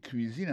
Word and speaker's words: cuisine: Autant de cuisine là-dedans, c'est comme ça on dit cuisine, on prononce cuisine en anglais. C-U-I-S-I-N-E. C'est cuisine.